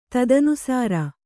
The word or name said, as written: ತದನುಸಾರ